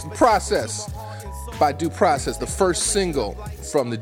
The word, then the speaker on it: Deux Process: Big up to Vice Verse, Chief Neck, and Sean Dub, three dudes in Deux Process. It's pronounced Deux Process, but it's spelled like the Frenchies spelled Deux, two. D-E-U-X, Process. Deux Process.